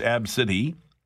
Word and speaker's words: ABCDE: Getting some reaction to the woman who called her child ABCDE, pronounced Ab-City.